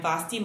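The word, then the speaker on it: Vasthy: I'm Vosti Mumpoint, rhymes with Frosty, if you get confused.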